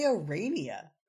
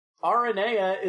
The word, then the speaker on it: Aranea: I say Arania. – Yeah? – I just had to know that pronunciation. – Aranea is the proper mythological pronunciation for that name.